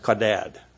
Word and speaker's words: chadad: The original word used for sharpens or sharpeneth, if you got the King James Version, in this passage is the term kadad. It's spelled in English C-H-A-D-A-D. It's pronounced kadad.